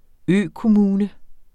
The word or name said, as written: økommune